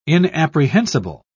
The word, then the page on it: inapprehensible: British English